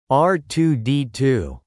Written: R2-D2

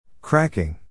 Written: cracking

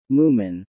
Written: moomin

